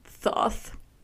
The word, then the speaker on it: Thoth: Sorry, Thoth. – Good pronunciation. – It's T-H-O-T-H.